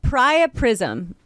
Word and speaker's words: priapism: Um, priapism.